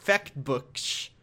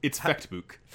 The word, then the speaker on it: Fechtbuch: Walpurgis Fechtbuch. There, I said it wrong. – It's Fechtbuch.